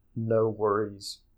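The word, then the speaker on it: NxWorries: And they go by the collaboration name No Worries, where the O in No is replaced with an X.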